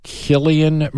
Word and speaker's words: Cillian: And his the Best Actor went to Cillian Murphy, I believe that's the Irish pronunciation, mm-hmm.